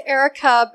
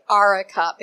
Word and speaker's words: Arica: So it's Erica, but when I try to tell someone how to spell my name, I say Arica because it's spelled with an A. Because okay. – Invariably, if I say my name is Erica with an A, they still spell it with an E.